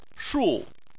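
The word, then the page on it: shu4: tone 4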